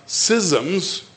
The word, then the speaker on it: schisms: They had schisms. I think it's spelled S-C-H-I-S-M-S. Is that right? – Yes. – S-C-H-I-S. Schism. Is that right?